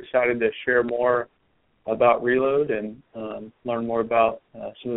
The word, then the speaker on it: Relode: Has had a soft launch going on for a while, but Matt is the CEO of Reload, and that is spelled R-E-L-O-D-E.